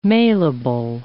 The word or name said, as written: mailable